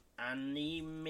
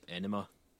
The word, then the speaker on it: anime: Anime? – I think it's pronounced Enema. – What? – Enema. – Enema, yeah. Anime.